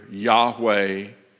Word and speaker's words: Yahweh: We pronounce it either Yahweh, which is the more modern pronunciation of that, but the older pronunciation coming out of the German that found its way in the King James is Jehovah.